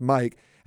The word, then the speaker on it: MMYYKK: Uh, but then on Friday night, it was a band called Mike M M Y Y K K, but it's pronounced Mike.